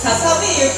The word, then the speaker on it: 細雪: ささめ雪」でした大変失礼いたしました皆さんも気をつけてくださいね細雪と書いてささめ雪と読みます、